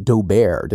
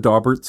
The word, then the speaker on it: Daubert: And some of them are, well, he starts asking him, he pronounced it Daubert, didn't he? Like it rhymed with Stephen Colbert. – Yeah, instead of the Daubert standard, yeah. – The Daubert. I've heard it both ways.